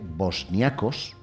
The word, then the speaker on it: bosniacos: un 75,2% de bosniacos, no lo digo mal. – No, se dice así.